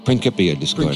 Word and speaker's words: Principia Discordia: I found a cheesy Xerox of the Principia Discordia. Did I pronounce that right? – Principia Discordia. – Principia Discordia.